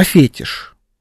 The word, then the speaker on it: фетиш: И там, ну, что-то какая-то была шутка, и он, значит, говорил там про фетиш. Почему я говорю фетиш, а не фетиш? Потому что он так и произносит фетиш. На английском.